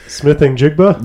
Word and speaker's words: Smith-Njigba: But Smith-Njigba.